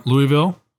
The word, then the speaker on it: Louisville: Anyway, Gary from Louisville, Kentucky. I think I'm pronouncing that wrong. – It's like, Louisville. – Louisville, I'm sorry.